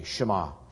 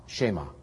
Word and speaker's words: Shema: It's spelled S-H and then a Hebrew character, M-A, Shema, Shema.